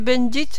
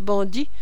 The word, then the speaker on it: Bendit: Monsieur Fabry, l'ingénieur des constructions, Monsieur Montbleu, le chef comptable, Monsieur Bendit, le commis pour la correspondance étrangère. Si vous parlez jamais à celui-là, ne manquez pas de l'appeler Monsieur Bendit. C'est un Anglais qui se fâche quand on prononce bandit, parce qu'il croit qu'on veut l'insulter comme si on disait voleur.